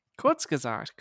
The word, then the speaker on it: Kurzgesagt: Kurzgesagt, come on. You should know that. – I am actually subscribed to them. – To Kurzgesagt? – Yes. – Yes, okay, good. – Kurzgesagt. – Kurzgesagt. – Kurzgesagt.